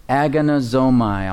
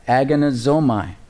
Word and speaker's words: agonizomai: In the Greek, it's the word agonizomai. I'll spell it for you. A-G-O-N-I-Z. O-M, as in Mary, A-I, agonizomai, A-G-O-N-I-Z-O-M-A-I. It sounds like the word what? Agony or agonize, right?